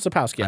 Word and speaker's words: Sapkowski: Andrzej Sapkowski? – Sapkowski, Andrzej.